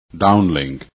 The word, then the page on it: downlink: english